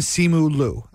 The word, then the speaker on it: Simu Liu: But the world loves Shang-Chi, and the star of Shang-Chi is an actor named Simu Liu. Am I saying that right, Kevin? His last name? – Yes.